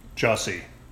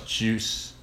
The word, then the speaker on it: Jussie: Jussie. – It sounds like Jesse. – Jesse is J-E-S-S, but his name is spelled J-U. – So it's Juice. – Juss. Like just. Jussie.